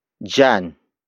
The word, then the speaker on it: diyan: And that is jan. Jan. Jan. Jan is spelled as D-I-Y-A-N.